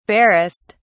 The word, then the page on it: barest: British English